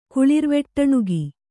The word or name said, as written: ಕುಳಿರ್ವೆಟ್ಟಣುಗಿ